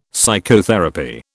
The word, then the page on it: psychotherapy: British English